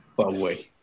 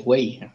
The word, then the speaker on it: Huawei: Se pronuncia Huawei. – ¿Quién puso ahí? Ah, Madison. Huawei. Getse Antonio dice que se pronuncia Wei.